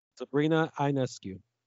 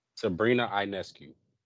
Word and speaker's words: Sabrina Ionescu: Sabrina Ionescu. – Sabrina Ionescu.